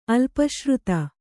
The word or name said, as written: ಅಲ್ಪಶ್ರುತ